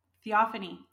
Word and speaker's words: Theophany: So I was reading that in the Orthodox Church it's called Theophany. Theophany. – Yeah. – I'm saying that right. I'm probably not saying that. Theophany? – Theophany.